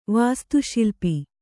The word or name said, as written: ವಾಸ್ತುಶಿಲ್ಪಿ